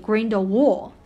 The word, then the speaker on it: Grindelwald: 一方面是因为他的姓啊，然后我这边正好岔开说一下，就是他这个姓呢叫 Grindelwald，因为它的发音更偏向于德语的发音，就那个 W 应该发成 V 的音。但是在美国的话呢，可能大家更加念的是 Grindelwald 这样子的，其实两个发音都 OK 啊。